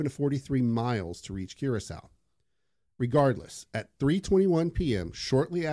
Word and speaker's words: Curaçao: I like how you know how to pronounce Curaçao because it's booze. – I might be actually be pronouncing it wrong. I'm just going with that. – No, no, I'm pretty sure that's exactly it.